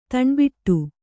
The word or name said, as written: ತಣ್ಬಿಟ್ಟು